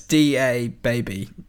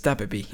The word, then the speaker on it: DaBaby: I think I'm saying it right. It's DaBaby, DaBaby. Yeah. Although it's there's no space, so it's DaBaby.